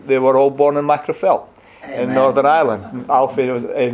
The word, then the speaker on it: Magherafelt: Macrophel is spelled M-A-G-H-E-R-A-F-E-L-T.